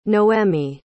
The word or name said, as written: noémi